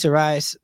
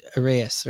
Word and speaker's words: Arraez: And, you know, if Luis Arraez, is that how I say his name? – No. – That was it. – That's the good guy. – Which, Arraez, right? – Yeah.